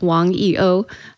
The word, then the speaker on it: Wang YiOu: In Mandarin, it would be pronounced Wang YiOu. In Chinese, you use your family name first.